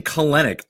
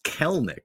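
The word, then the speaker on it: Kelenic: He is in line to start tomorrow, and so we've been saying Kalenic. I believe it's pronounced Kelnick, which I don't like as much.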